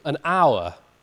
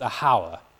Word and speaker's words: hour: And this is a word which is pronounced in modern English without the H. It's written with the H, the word hour, but it's never pronounced with the H. No one calls it a hour.